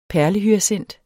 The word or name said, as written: PERLEHYACINT